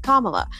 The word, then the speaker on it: Kamala: calling her Kamala, and they corrected him in a very polite, normal way. They're like, oh, I believe it's pronounced Kamala.